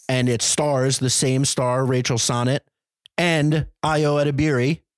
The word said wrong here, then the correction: Sennett: It's Sennett. Damn it. I knew I said it wrong.